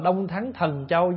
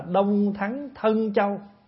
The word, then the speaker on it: Đông Thắng Thân Châu: Ví dụ như cõi Đông Thắng Thân Châu, phía đông của Tu Di núi, Có nhiều chỗ người ta đọc là Đông Thắng Thần Châu, nhưng mà không đúng. Đọc vậy không đúng, phải đọc là Đông Thắng Thân Châu.